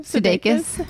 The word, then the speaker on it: Sudeikis: Sudeikis. – Have you heard anything from him after your just shout out directly into the screen? The camera lens. – They were like, they said, Jason Sudeikis is watching right now.